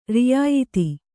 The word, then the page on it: ರಿಯಾಯ್ತಿ: riyāyti